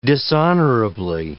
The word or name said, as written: dishonorably